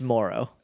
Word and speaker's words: Mauro: Am I saying that right? – I think it's Moro. – Moro Ranallo. – Could be either.